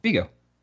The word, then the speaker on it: Viggo: Is it Viggo? – Viggo. – Okay. – Like from Ghostbusters.